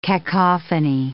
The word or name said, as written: cacophony